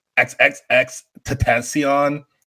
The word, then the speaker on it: XXXTentacion: So my next person, and honestly, I might say this person and it changed my mind, to be honest, but XXXTentacion. Is that how you say his name? – Yeah.